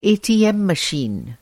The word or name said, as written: एटीएम मशीन